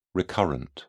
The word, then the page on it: recurrent: British English